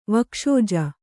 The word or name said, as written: ವಕ್ಷೋಜ